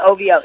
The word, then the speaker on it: OVO: Am I pronouncing it right? – OVO? Because my daughter said. OVO. – Uh, it is OVO.